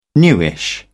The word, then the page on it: newish: British English